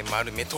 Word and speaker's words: MALMO: MALMO、これでマルメと読むんですね。 – これでマルメと呼びます、はい、なんか読み方がちょっとどう読んでいいかわからなかったので、でも素敵な名前ですね。